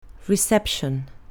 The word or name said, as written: reception